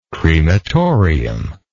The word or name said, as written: crematorium